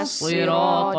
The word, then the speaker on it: الصراط: اهدنا الصراط المستقيم طا طا طا Put your tongue at the back of your front of a teeth طا طا Make no like how do you say like make your tongue go this way okay